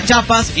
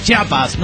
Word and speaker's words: Chiapas: Les aclaro Chiapas porque hay cada pendejo que pronuncia Chiapas y no Chiapas. No, las Chiapas son otra cosa.